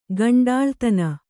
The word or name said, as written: ಕುಂಟಲತನ